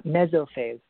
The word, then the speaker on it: mesophase: Yeah, mesophase. So meso is from the Greek word that means to be in the middle of. So it's a state in between liquidity and crystallinity.